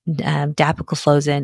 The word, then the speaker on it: dapagliflozin: Canagliflozin, dapagliflozin, and empagliflozin. All right. So with that, nice job with the pronunciation.